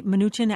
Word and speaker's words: Mnuchin: Mnuchin actually is how you say his name.